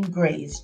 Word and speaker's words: Graves: Google Carmiece, C-A-R-M-I-E-C-E. Again, that's spelled C-A-R-M-I-E-C-E. Last name Graves, G-R-A-V-E-S.